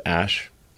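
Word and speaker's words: Æ: I mean, it's just X, the letter X, and then the AE is like pronounced Ash.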